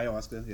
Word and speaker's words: ayahuasca: You take the uh, ayahuasca. I said that correctly, did I? Uh, uh, yeah, ayahuasca.